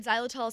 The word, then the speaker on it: xylitol: So our main ingredients for the magic teeth cleaners are xylitol and erythritol. Granted, xylitol is spelled with an X, but it sounds like a Z, and kids think it's a Z, so, you know, Zs are fun.